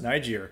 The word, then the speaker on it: Niger: No other pronunciation for that, but it's Niger.